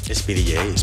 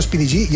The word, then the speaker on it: Speedy J: Speedy J. – Speedy J, ¿no? Yo siempre he dicho Speedy G y es Speedy J, claro, porque es una J, no una.